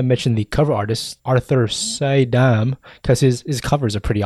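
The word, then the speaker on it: Suydam: It's like Soydam. – Soydam.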